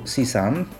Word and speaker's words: CSUN: Tak, to jest de facto skrót, natomiast skrót ten się stał takim, po prostu takim sloganem, natomiast pisze się to CSUN, po angielsku CSUN, tak to oni czytają,